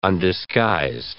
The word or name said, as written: undisguised